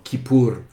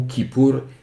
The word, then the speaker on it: Kippour: Vous avez souvent deux orthographes, donc Kippour, K-I-P-P-U-R, ou Kippour, K-I-P-P-O-U-R. Ça se prononce de la même façon, c'est simplement que c'est un terme en hébreu, donc le mettre dans une phonétique occidentale, ça va dépendre de la langue qui est la vôtre.